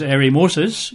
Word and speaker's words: eremosis: And in terms even of the language, the word abomination in Greek is this rather horrible word to pronounce, bedeligma, B-D-E-L-Y-G-M-A. The word desolation in the Greek is eremosis, E-R-E-M-O-S-I-S.